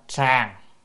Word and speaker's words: sàng: Nhược y bì chữ này đọc là sàng.